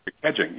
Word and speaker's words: kedging: And is that how you pronounce it, the nautical term? Kedging? – Kedging, yes. That's the old nautical term.